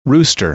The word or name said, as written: rooster